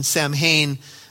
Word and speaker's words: Samhain: And so they began to celebrate a holiday called, it's spelled S-M, S-A-M-H-A-I-N, Samhain but as you read a little bit more on, on this, they say it's pronounced Samhain.